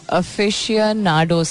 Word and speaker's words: aficionados: मैं इसकी pronunciation सही तरह देखती हूँ. Aficionados. सही तो कह रही हूँ मैं.